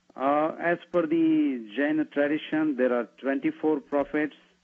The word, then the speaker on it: Jain: No, just Jain.